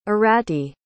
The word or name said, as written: Arati